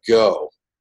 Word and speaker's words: gho: And so the men wear something called a go. I believe it's spelled G-H-O.